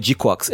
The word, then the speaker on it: GQuuuX: É assim que se pronuncia? – É G-Quax. É G-Q-U-U-U-U. Eu não sei quantos U são. U-U-U-U-X. – Acho que no anime eles falam de Quax mesmo.